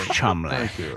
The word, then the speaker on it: Cholmondeley: The Marchioness of Cholmondeley. – Exactly. The Marchioness of Cholmondeley.